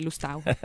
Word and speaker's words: Lustau: Okay, so, and Lustau, is it Lustau or Lusto? Or? – I guess in the past it was Lusto, because mm-hmm. it's French origins, but nowadays the Spanish pronunciation would be Lustau.